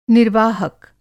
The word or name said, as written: निर्वाहक